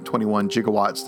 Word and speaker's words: gigawatt: gigawatts that they need to go back to the future. And by the way, yes, I understand that it's pronounced gigawatt.